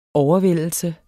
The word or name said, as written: overvældelse